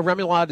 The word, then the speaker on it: remoulade: Is it remoulade? Am I pronouncing that correctly? – Remoulade is correct.